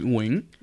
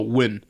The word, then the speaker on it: Nguyen: So how you say it in Vietnamese is Nguyen, mm-hmm. right? And then English people, like, when just in English, we just go win. But me, I'm like, there's six whole letters. There's not a W. Where the fuck do you get win? So I kind of, you know, just take the liberties. And the way I pronounce my last name is Nugent. – Mm-hmm. – Right. And she's like, you are so whitewashed. It's pronounced win or wing.